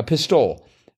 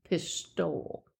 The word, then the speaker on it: pistole: Yeah, pistole. – It looks like the accent is on the first syllable, and it's a long O.